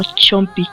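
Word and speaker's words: Soompi: Sí, no se olviden que las votaciones para los Chompy, no sé por qué dije Zompy en las rápidas, pero se pronuncia algo así como Chompy.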